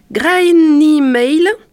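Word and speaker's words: Gráinne Mhaol: C'est une femme pirate et une figure historique irlandaise, et je m'excuse par avance pour essayer de prononcer son nom entier à l'irlandaise. Donc, de son nom irlandais, Graine Maile.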